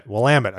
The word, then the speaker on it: Willamette: Willamette.